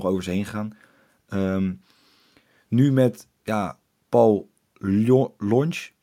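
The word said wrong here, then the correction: Llonch: moet ik voor mij zeggen, want of de L spreek je niet uit, toch? – Jonch, ja. Lonch. Ja, je, twee L's spreek je uit als een J.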